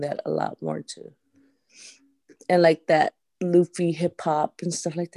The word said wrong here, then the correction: lo-fi: I think I'm just, it is pronounced usually lo-fi, but I think lo-fi.